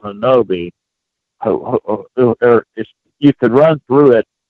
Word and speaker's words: Honobia: Hanobi, Oklahoma, it's spelled H-O-N-O-B-I-A. And okay. Some people say Hanobia.